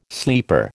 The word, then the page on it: sleeper: American English